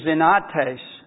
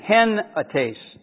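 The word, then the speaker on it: henates (ἑνότης): The Greek word is enates, enates, or actually henates. There's a little thingy in front of the E which means you automatically insert an H. And it's pronounced henates, and it means what?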